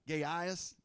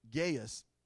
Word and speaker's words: Gaius: You know, I always used to pronounce it Gaius for some reason, but the proper pronunciation is Gaius. The I is supposed to be like a Y, Gaius, okay?